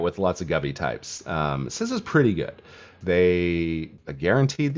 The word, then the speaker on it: CISA: And you pronounce it CISA, which is unlike how you pronounce pizza or CISA or anything. Like, so anyway, they have a weird pronunciation because it's government.